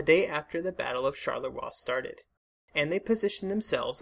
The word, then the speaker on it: Charleroi: Last week, we discussed General Lanrezac and his French Fifth Army as they dealt with the German attack at Charleroi. I would like to thank listener Stephen Mitchell for pointing out that I have been horrifically mispronouncing Charleroi in previous episodes.